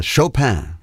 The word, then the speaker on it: Chopin: and Chopin is the real correct way of pronouncing it,